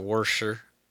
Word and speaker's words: Worcester: Well, I won't be making it to Worcester, but I'll throw it in the show notes. – Worcester? – It doesn't even look like that. How do you pronounce it that way? It doesn't make any sense.